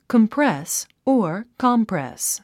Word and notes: compress: kəmprés; コンプレス

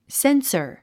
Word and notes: censor: sénsə r; センサァ

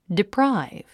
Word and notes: deprive: diprǽiv; ディプラァイブ

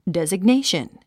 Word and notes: designation: dèziɡnéiʃən; デザグネェション

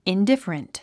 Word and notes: indifferent: indífərənt; インディファレント

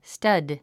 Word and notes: stud: stʌ́d; スタド

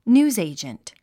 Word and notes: newsagent: njúːzèidʒənt; ニューズエィジェント

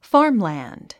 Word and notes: farmland: fɑ́ːrmlæ̀nd; ファームランド

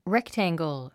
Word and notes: rectangle: réktæ`ŋɡl; レクタングゥ

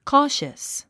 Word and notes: cautious: kɔ’ːʃəs; コーシャス

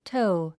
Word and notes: tow: tóu; トウ

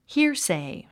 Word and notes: hearsay: híə r sèi; ヒアセイ

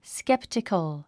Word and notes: skeptical: sképtik ə l; スケプチコゥ

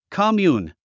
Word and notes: commune: kəmjúːn; コォミューン

